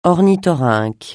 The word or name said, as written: ornithorynque